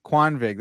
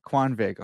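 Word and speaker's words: Kvanvig: Can you say it again? – Quanvig. – That's good. I said it like an idiot before. Quanvig. That's okay. Say it like a W. Yeah. – Yeah. Quanvig.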